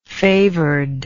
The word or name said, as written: favoredness